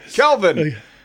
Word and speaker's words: Kelvin: Then we'll have a conversation tomorrow, and we'll say, well, you know, what a great defensive effort that Kevin Sampson put together. Oh, Kelvin, know Kevin, my name. Say it right.